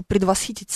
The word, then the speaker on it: предвосхитить: Вы же знаете, например, что в слове «предвосхитить» ударение именно такое. Вот, да, я хочу предвосхитить события и проанонсировать, к примеру, приход Петра Кузнецова через 15 минут в эту студию.